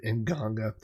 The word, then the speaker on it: Nganga: And one of his bands was a death metal band named Nganga, which is spelled, if you're curious, N-G-A-N-G-A, Nganga.